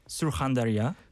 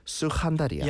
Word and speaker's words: Surkhandarya: My hometown is pronounced Surkhandarya. – Surkhandarya.